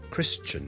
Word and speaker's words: Christian: 而基督徒 Christian 我哋上次讲咗，亦都有一啲又系老一辈嘅人咧，可能会读成咧 Christian，嗰个发音好似 tian 咁，你当系好似人啦，跟住前面加好轻嘅 t 音 tian，OK，咁但系呢个发音咧系唔需要学嘅，OK，咁但系咧听到就唔好玩错，OK，Christian question，譬如 I'd like to answer your question。